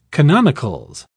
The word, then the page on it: canonicals: British English